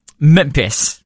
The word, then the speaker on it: Memphis: Memphis. – Thank you. – Memphis. – I thought I got that. – There Memphis. – Isn't an R there. – Sorry, I was pronouncing it phonetically.